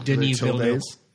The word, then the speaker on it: Denis Villeneuve: the director is Denis Villeneuve. I think I said that right. – Denis Villeneuve. – Yeah, because he's French.